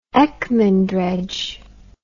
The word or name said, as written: Ekman dredge